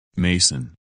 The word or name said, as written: Mason